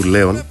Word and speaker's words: Λέων: Συμπληρώνονται σήμερα 18 χρόνια από την απώλεια του Λέων Αυδή, όπως ο ίδιος ήθελε να γράφεται και να προφέρεται το όνομά του, Λέων.